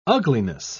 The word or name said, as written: ugliness